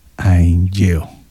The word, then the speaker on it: haenyeo: La palabra es Ainjeo, que se escribe con H-A-E-N-Y-E-O, Ainjeo.